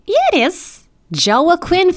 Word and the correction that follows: Joaquin: Phoenix. That's his name. – No, that's how it's spelled, all right? Yeah, it's pronounced Joaquin Phoenix.